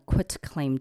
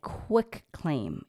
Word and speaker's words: quit claim: This process is called getting a quit claim deed, not a quick claim. Everybody always mispronounce that. It's quit, Q-U-I-T, quit claim deed.